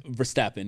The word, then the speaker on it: Verstappen: Verstappen, yeah.